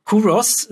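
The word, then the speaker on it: Culross: Kuros, das L spricht man gar nicht, habe ich mir dort erklären lassen.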